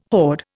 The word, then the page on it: horde: Indian